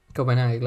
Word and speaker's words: Copenhague: Copenhague, claro. – Eh, sí, claro que se pronuncia así, no, no, estoy seguro.